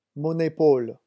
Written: mon epaule

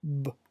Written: b

